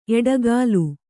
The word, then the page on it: ಎಡೆಗೋಲ್: kannada